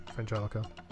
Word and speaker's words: Frangelico: And Frangelico. Or I'm probably pronouncing that one wrong too. – No. – Oh, really? – Yeah, Frangelico.